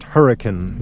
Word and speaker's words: Hurricane: Now, it's spelled Hurricane, but it's pronounced Hurricane. That's how the locals pronounce that.